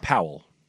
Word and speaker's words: Pawol: Oh, it's pronounced Powell. – Is it Powell? – Powell. – Okay. Jen Powell. It's spelled P-A-W-O-L, which is a bit obnoxious.